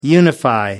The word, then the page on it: unify: American English